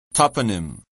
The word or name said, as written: toponym